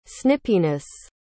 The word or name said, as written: snippiness